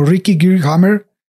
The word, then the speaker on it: Geerd Hamer: Busqué así en varios lados y en Alemania lo pronuncian como Ricky Gearhammer. Este, y bueno, él nació. Gearhammer.